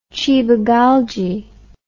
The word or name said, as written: cibageigy